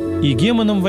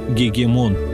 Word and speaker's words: игемон: Игемоном в античности было принято именовать правителя государства или какой-то географической области, как в случае с Пилатом, от лица империи, руководившим Палестиной. Игемон в ином произношении звучит как «гегемон».